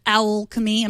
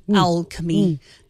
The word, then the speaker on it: Owlchemy: Owlchemy, I'm not just pronouncing alchemy wrong, it's OWL, like an owl. Owlchemy.